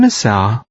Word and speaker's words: الساعة: كم الساعة؟ – Pay special attention to the final sound in الساعة.